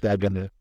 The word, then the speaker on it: Dugan: Is it Duggan or Dugan? D-U-G-A-N. I don't even know how to pronounce it. – Duggan.